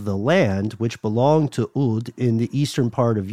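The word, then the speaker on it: Ud (ʿĀd): So Ubar has long been associated with a group of people called the Ud, spelled like apostrophe A-D, but pronounced more like Ud.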